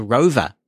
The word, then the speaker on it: Rova: "I've also briefly used that station's app called Rover." It's spelled R-O-V-A.